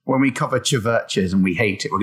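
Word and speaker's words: Chvrches: It would be, I don't know how to pronounce this, Churches? Was it just Churches? But it's with a V instead of the U. – Yeah, just Churches. Yeah. – Is it just pronounced Churches? – It's just a stylistic thing.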